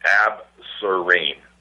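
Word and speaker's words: Absorene: So A like Alpha, B like Bravo, S like Sierra, O like Oscar, R like Romeo, E like Echo, N like November, E like Echo. Ab Serene.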